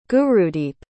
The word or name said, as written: gurudeep